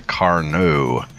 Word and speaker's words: Carnot: This is a French cruiser, the Carnot. – With a Carnot. – Well, being French, they don't pronounce T's, remember? – I know.